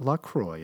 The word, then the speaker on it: LaCroix: How is LaCroix pronounced? LaCroix. It rhymes with enjoy. Remember that.